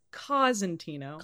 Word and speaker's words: Cosentino: It's Cosentino.